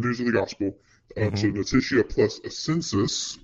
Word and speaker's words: notitia: But Reformed theology analyzes faith as notitia.